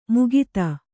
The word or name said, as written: ಮುಗಿತ